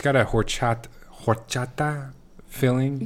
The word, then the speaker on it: horchata: It's horchata, but pronouncing the H. – It's horchata. It's not horchata. Horchata? No H. I, I want, mean, there is an H, but you don't.